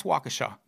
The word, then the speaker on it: Waukesha: It's pronounced Waukesha.